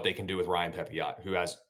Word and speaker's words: Pepiot: You might want to say Ryan Pepiot, but it's Ryan Pepiot. That is how you say it.